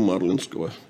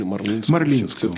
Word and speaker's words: Марлинского: И здесь же, конечно, нужно обратиться и действительно к декабристам и вспомнить знаменитую фразу, опять я процитирую, Бестужева Марлинского. Или Марлинского. – Марлинского. – Я почему-то все время привык произносить Марлинского. У каждого интеллигентного человека есть свой любимый набор ошибочных ударений.